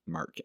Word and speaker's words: Marque: Marque, M-A-R-Q-U-E, I think is how you say it, Marque.